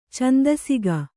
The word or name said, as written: ಛಂದಸಿಗ